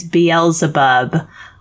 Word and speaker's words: Beelzebub: Because Beelzebub is not the correct pronunciation. He had reversed the Z and the L. The correct response here is Beelzebub.